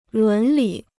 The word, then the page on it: 伦理: lún lǐ